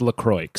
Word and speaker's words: LaCroix: You know, like, obviously it's pronounced LaCroix.